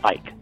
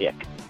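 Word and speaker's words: Icke: Although, his fans insist you pronounce it Ike, not Ick.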